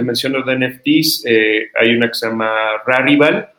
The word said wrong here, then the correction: Rarible: perdón, Rarible, iba a intentar pronunciarla en español, pero es A-R-I-B-L-E.